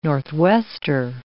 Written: northwester

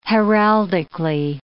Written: heraldically

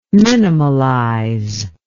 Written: minimalize

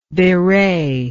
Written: bewrayer

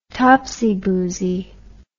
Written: topsy boozy

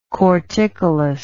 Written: corticolous

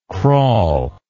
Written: crawlingly

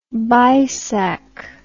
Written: baisakh

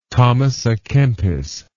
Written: thomas a kempis

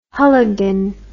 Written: hulligan

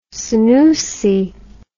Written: senusi

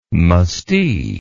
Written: mustee

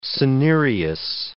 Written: cinereous

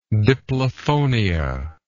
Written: diplophonic

